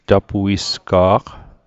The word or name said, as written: tapuisga'q